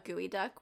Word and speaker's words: geoduck: Why did they call it a gooey duck when it's spelled geoduck? – Right, that's what I really want to know.